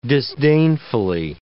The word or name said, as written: disdainfully